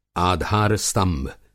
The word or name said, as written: आधार-स्तंभ